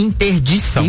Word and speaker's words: interdição: Ponto de interditação. – De interdição.